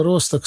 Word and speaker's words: Росток: Если он местный, название города Росток само за себя говорит. Немцы в нем только ударение поменяли с последнего слога на первый.